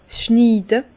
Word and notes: 's Schniide: Bas-Rhin; Herrlisheim

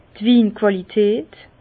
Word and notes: d' Winquàlität: Bas-Rhin; Herrlisheim

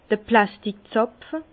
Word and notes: d'r Plasttik Zàpfe: Bas-Rhin; Herrlisheim

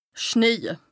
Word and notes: schneje: Bas-Rhin; Strasbourg

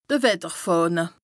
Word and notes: de Wetterfàhne: Bas-Rhin; Strasbourg

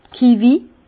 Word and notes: d' Kivis: Bas Rhin; Herrlisheim

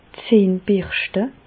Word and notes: d' Zähn birschte: Bas Rhin; Reichshoffen

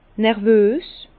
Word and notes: nervös: Bas-Rhin; Herrlisheim

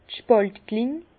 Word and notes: d' Spàltkling: Alsatian; Bas-Rhin; Herrlisheim

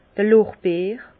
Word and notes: d'r Lorbeer: Bas Rhin; Herrlisheim

